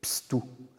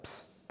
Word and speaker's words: pstu: Par exemple, euh, le, mot pour homme en miyako va être pstou, avec un ps dans la première syllabe. Donc on a deux syllabes, pstu.